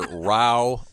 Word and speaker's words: row: It says here, row, when meaning a disagreement, rhymes with now.